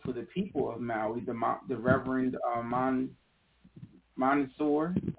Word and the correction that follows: Monsignor: I think is how they pronounce that, Terrence Monsignor.